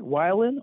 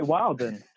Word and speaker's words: wildin': You either say it like you're saying it with a Y, Wylin, or you say Wilden. And in Utah, we kind of say Wilden.